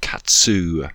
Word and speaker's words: Qattuu: Her name is Katsu. It's spelled Q-A-T-T-U-U.